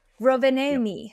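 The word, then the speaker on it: Rovaniemi: It's Rov, Ro Rovane Rovanemi? Oh my god. – Sure. – Rovanemi.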